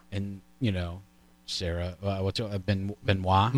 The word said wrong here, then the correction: Benoist: Melissa Benoist Melissa Benoist. I never know how to pronounce it. – There's an S in there.